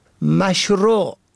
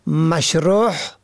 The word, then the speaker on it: مشروع: به گونه مثال مشروع که معنای آن آنچه مطابق شرع است را شماری مشروع یا مشروح به هی حلقی تلفظ می کنند که شرح شده معنا می دهد.